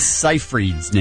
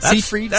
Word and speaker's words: Seyfried: How do you say Amanda Seyfried's name? Is that how you say it? Seyfried? Seyfried? – Close enough for me.